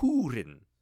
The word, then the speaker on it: Húrin: And that was one of my hard takeaways was, it's not like H-E-W, which is how I would interpret it being pronounced with that umlaut or whatever you call that accented U. Instead, it is H-O-O. Not Húrin, which I think sounds way cooler, but it's Húrin, like an owl.